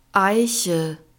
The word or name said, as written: eiche